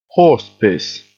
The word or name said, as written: horsepiss